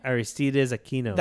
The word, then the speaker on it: Aristides Aquino: Aristides Aquino.